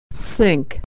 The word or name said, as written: sink